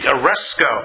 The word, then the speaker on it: aresko: That's how you pronounce it in Greek, aresko. I like that because it almost sounds like you're going to arrest somebody. That's how I remember how to pronounce it.